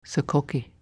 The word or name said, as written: Sokoki